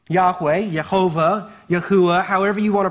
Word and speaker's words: Yahweh: And so the most common way we end up pronouncing this and where translations choose to leave this in, the most common way they translate that is Yahweh. But just for your education, I want you to know there's other ways that you might hear people pronounce it, and they're not necessarily wrong. So another common way you might hear it pronounced is Yehovah or even Yahuwah. And then a shortened form of it would be Yah.